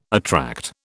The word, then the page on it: attract: British English